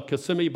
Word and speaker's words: Kissimmee: Well, Kissimmee by day, but kiss me by night is what they used to say.